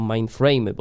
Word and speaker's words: mainframy: Ten system operacyjny wywodzi się z Unixa, czyli takiego systemu na duże, duże komputery, tak zwane mainframy, albo mainframy, bo to tak się powinno wymawiać.